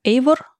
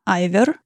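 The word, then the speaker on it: Eivør: Там их всего три, и это то, что понравилось настолько, что вот просто Ох, Никита Дубко прислал группу, которую я сначала прочитала как Эйвор, оказалось, что правильно, скорее всего, произносить это Айвер.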